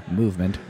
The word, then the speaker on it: MVMT: But in the meantime, keep telling me about Movement Watches. – Okay. The pricing along with Movement's classic... it's written MVMT is the way that you spell it, so I keep wanting to say that.